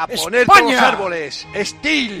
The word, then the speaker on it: STIHL: Steele se escribe S-T-I-H-L.